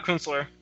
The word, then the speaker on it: Kunstler: Like, you go, I think Kunstler, is that how you pronounce his name? – Yeah, Kunstler.